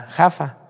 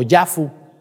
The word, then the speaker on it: Jaffa: Se llama Tel Aviv y a un lado está Jaffa o Yafu, como ustedes le quieran decir. Jaffa se dice con J o Yafu con Y, ¿no? pero así se pronuncia en hebreo actual, actualmente.